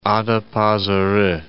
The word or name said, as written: Adapazari